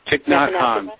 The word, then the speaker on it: Thich Nhat Hanh: and I don't know if I'm pronouncing this right, but Thich Nhat Hanh, Yeah, Thich Nhat Hanh, right, right.